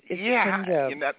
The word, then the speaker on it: Punjab: It's Punjab.